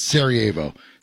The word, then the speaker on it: Sarajevo: But, yes, that's why I remember it's Sarajevo.